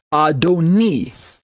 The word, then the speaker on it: Adoni: Adonai versus Adoni. It's the vowel sound at the very end, the second syllable vowel sound.